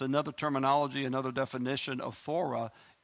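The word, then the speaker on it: thura: The word door, I'm going to spell it for you, is T-H-U-R-A. T-H-U-R-A. Thora. Okay? That's 2374. Thora. 2374.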